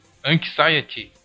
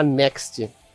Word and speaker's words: Anxiety: Como é que é a pronúncia? Separation? – Anxiety. – Cara, eu falava next. Eu falava. Eu falava next porque eu não conseguia ler aquela palavra.